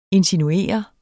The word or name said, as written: INSINUERET